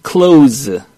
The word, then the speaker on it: close: Close인데 뒤에 SE가 있죠. 이거 Z 발음을 합니다. Z. 어떻게 해요? Close. – Close. – 그렇죠. Close가 아니에요.